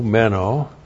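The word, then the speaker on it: meno (μένω): M-E-N, long O, meno.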